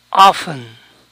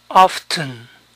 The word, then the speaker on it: often: Often. Often. También se puede pronunciar la T. Often.